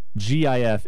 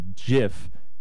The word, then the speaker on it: GIF: If it doesn't stand for anything, and if he wanted it to be GIF... He should have spelled it J-I-F, but it's spelled G-I-F because it does stand for something. Graphics Interchange Format. – Okay. And so, graphics. – No. – Hard G.